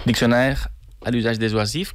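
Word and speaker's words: Dictionnaire à l'usage des oisifs: Doncs seguim escoltant una altra peça teua, que no sé si es diu Dictus Oisifs. Ho he pronunciat bé? – Diccionaire à l'usage des oisifs,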